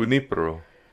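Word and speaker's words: Junípero: which was founded by St. Junipero? Did I say that right? – Junipero.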